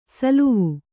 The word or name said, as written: ಸೋಲುವೆ